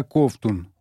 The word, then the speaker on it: Ковтун: Фамилия была Ковтун, ударение на первом слоге. И не дай бог произнести ее иначе.